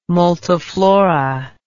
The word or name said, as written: multiflora